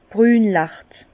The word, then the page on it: brünlacht: Bas-Rhin; Herrlisheim